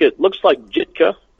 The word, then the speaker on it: Jitka: Her name, it looks like Jitka. It's spelled with a J, but the J, of course, is silent because she's Czechoslovakian and very proud of it, by the way.